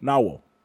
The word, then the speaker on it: Nahual: Both are pronounced Nahual in English. N-A-W-A-L. So they're pronounced Nahual.